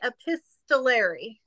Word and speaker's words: epistolary: Yeah, so you think of the epistles in the Bible, right? Letters by Paul. And say epistolary.